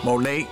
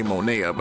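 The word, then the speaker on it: Monét: Monet, I keep wanting to say Monea, but her name is Monet. Monet, M-O-N-E, accent A.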